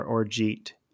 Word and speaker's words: orgeat: And the orgeat syrup, which is spelled O-R-G-A-T. I never knew how to pronounce it. I always thought called it orgeat or orgeat. It's orgeat is the right pronunciation.